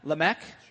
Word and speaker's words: Lamech: Noah's father was Lamech. Is that how you pronounce that? Lamech? – Sure.